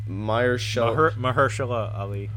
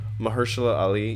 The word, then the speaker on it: Mahershala Ali: Mahershala Ali. – Mahershala Ali.